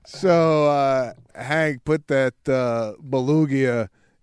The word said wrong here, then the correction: beluga: in the library for words mispronounced. It's beluga.